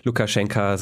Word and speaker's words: Lukaschenka: hattest gesagt, du hast dich nochmal schlau gemacht und Lukaschenka sei eben die belarussische Variante und Lukaschenko die russische Variante. Das äh, scheint nicht so ganz richtig zu sein.